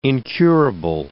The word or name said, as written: incurable